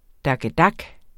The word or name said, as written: dakkedak